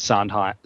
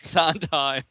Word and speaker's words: Sondheim: But apparently the H is not supposed to be aspirated. So for all of you who, like me, have been saying it wrong for 45, 50 years, for whatever that's worth, if you want to appear in the know, it's Sondheim, not Sondheim.